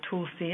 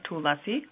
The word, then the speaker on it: Tulsi: In India, they eloquently pronounce it Tulsi or Tulasi.